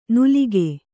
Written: ನುಲಿಕೆ

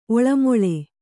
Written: ಒಳಮೊಳೆ

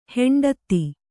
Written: ಹಿಂಡೆತ್ತು

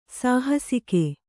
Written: ಸಾಹಸಿಕೆ

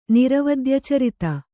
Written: ನಿರವದ್ಯಚರಿತ

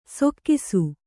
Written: ಸೊಕ್ಕಿಸು

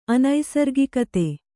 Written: ಅನೈಸರ್ಗಿಕತೆ

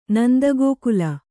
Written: ನಂದಗೋಕುಲ

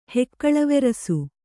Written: ಹೆಕ್ಕಳವೆರಸು

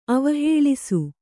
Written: ಅವಹೇಳಿಸು

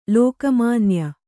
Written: ಲೋಕಮಾನ್ಯೆ

